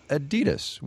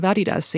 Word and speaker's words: Adidas: You've been saying Adidas, as in Adidasler, Adidas. Over here we say Adidas. Which one's right? – Oh, definitely the European pronunciation, and it's really strange. I really can't explain why you pronounce it differently in the US.